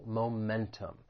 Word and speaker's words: momentum: The word has three syllables. The stress is on that second syllable, momentum.